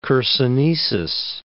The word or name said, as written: Chersonesus